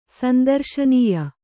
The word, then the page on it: ಸಂದರ್ಶನೀಯ: Kannada